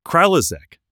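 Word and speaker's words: Kralizec: This word. Kralizek. – Yeah. – Really? – Think that's right. That's how they say it in the audiobook.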